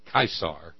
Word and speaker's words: Caesar: And thus did Caesar, Caesar, the correct Latin pronunciation, Caesar, huh? Sounds like Kaiser, sounds like Tsar, yeah?